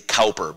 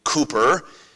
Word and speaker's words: Cowper: In English, in American English, we might say Cowper, because that's what it looks like. William Cooper, in the British pronunciation, wrote "There is a fountain filled with blood."